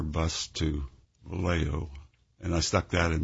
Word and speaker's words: Vallejo: And he paused, and he said, I'll tell you, here in California, it's called Vallejo. We pronounce the J's as H's. It's called Vallejo.